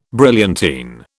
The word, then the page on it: brilliantine: British English